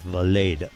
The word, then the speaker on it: Vlade: Vlad gets to the 10 for a pickup of 3. Second and 7. Well, Cleary said Vlade, so that's what we'll go with. Vlade, long A.